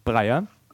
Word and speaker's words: Breyer: Breyer, wie spricht man es richtig aus mit den Nachnamen? – Breyer.